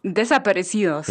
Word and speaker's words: desaparecidos: den Desaparecidos, oh nee, das war schon nix, den Verschwundenen, vielleicht kannst du es nochmal auf Spanisch aussprechen. – Desaparecidos. – Ja, das klingt genau. Schon besser als bei mir.